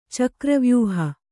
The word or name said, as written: ಚಕ್ರವ್ಯೂಹ